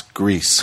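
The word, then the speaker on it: Gris: And I learned as I was watching that that's how you pronounce Grease. – Grease, yeah. – Which is, I thought it was pronounced Gree. – Gree. – No, we had this discussion because Grease is Spanish for gray.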